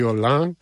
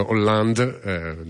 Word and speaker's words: Hollande: Perché non pronunci la D di Hollande? Ascolta Giorgini e altri se non ne sei sicuro. Va bene, se sei convinto che bisogna dire Hollande, diltela così.